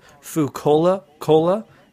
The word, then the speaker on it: Fukola Cola: It's called Fucola Cola. It's spelled Fukola Cola.